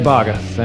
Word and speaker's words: Baugues: It's Greg Baugues, and it's actually a point of contention amongst even my family members, so don't feel bad about that.